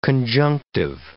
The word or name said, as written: conjunctive